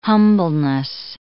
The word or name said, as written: humbleness